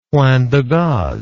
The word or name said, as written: point de gaze